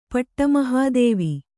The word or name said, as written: ಪಟ್ಟಮಹಾದೇವಿ